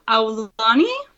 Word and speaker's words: Aulani: And also, I have been falling in love with the Hawaiian resort, and I'm going to butcher this. The Aulani? – You got it.